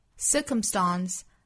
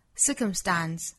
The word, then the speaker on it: circumstance: 这个词呢，在英音之中有三种读法，区别在于最后一个音节。 – Circumstance, circumstance, 或 circumstance. – 最后一个音节分别可以读成梅花 a, dance. 后元音的 a, dance, 或刷嗓的 a, dance.